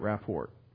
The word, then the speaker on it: rapport: R-A-P-P-O-R-T. It's not what you get from Experian, your credit rapport. It is, the T is silent, rapport.